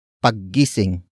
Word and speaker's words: paggising: Ang is the focus marker that introduces the main topic of the sentence, and that is paggising. Paggising. Paggising. Paggising is spelled as P A G G I-S-I-N-G.